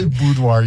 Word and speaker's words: boudoir: Boudoir. Isn't it? That's how you say it, right? – Boudoir, yes.